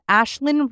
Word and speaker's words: Aisling: Ashlyn, her name is spelled A-I-S-L-I-N-G. So anyway, there's Ashlyn Reed with 2 E's,